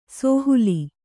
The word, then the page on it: ಸೋಹುಲಿ: Kannada; sōhuli